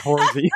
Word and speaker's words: Whoresy: Yeah, but I spell it W-H-O-R-E-S-Y, horsey.